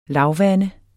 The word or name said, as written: lavvande